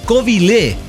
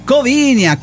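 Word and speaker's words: Covilhã: Es Covilé. Covilé, porque usted, pirata, dice Covinia, Covila.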